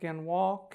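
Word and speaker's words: Galois: Okay, last thing before we get to code, I'll talk a little bit about the AES, it's pronounced Ganwal counter method. Ganwal, or G-A-L-I-O-S, they're a mysterious company in Portland, about 50 people, supposed to be really smart.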